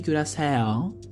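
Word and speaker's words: Curaçao: Το νησί δεν λέγεται Κιουρασέλ, όπως το έλεγα εγώ. Λέγεται Κιουρασάο, απλά προφέρεται Κιουρασέο, κάπω έτσι. Στα ελληνικά Κουρασάο το γράφει, σαν κρουασάν.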